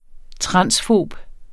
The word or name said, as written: transfob